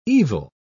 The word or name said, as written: evil